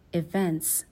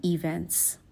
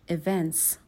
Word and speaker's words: event: به تلفظ کلمه event هم دقت کنین. استرس کلمه روی بخش اول نیست. استرس کلمه روی بخش دوم کلمه است. میگیم events نمیگیم events. تلفظ درست این هستش events.